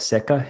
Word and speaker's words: Sekka: So there's one in particular called Seka Hinoki. It's spelled S-E-K-K-A.